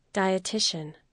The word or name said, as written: dietician